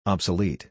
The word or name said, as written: obsolete